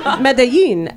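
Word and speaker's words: Medellín: Medellin, Escobar country.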